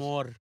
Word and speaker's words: Namor: Namor. Say it right now.